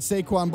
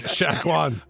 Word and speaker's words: Saquon: You butchered Saquon Barkley's name, which you did. – Shaquan. – Called him Shaquan Barkley.